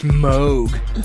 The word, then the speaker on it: Moog: I think it's pronounced Moog.